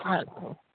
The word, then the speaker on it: Hansen: It's Hansen.